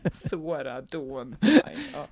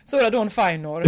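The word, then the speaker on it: Sarah Dawn Finer: Det är fyra ån i Sora Dawn Finer. – Ja. Det är, men, all, men det är inga riktiga ån. Det är bara uttalsmässigt. – Nej, det är brittiska ån. – Det är brittiska ån, ja.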